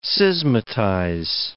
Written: schismatize

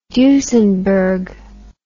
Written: Duesenberg

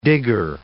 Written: digger

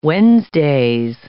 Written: Wednesdays

